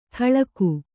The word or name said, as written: ಥಳಕು